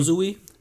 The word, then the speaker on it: Uzui: How do you say Tengen's last name? – Uzui.